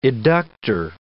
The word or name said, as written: eductor